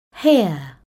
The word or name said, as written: hear